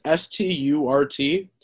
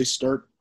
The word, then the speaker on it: Sturt: Last name is Sturt, but it's pronounced like Stuart, like if there were an A, but there is no A.